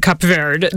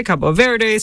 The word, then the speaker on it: Cabo Verde: One, we just heard the man himself say Cape Verde, the right. English pronunciation. Yes. You and I are going with Portuguese, which That's is right. what people there would say, Cabo Verde, same country.